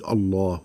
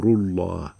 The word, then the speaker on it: الله: الله لفظ الجلالة ينطق بتفخيم اللام عندما تبدأ الجملة به الله أكبر وعندما تسبقه ضم هذا أمر الله أو فتح أطعنا أمره امر الله واما اذا سبقته كسره هذا من عند الله فترقق